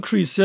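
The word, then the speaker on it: Kreese: John Crease, ja, ja, genau.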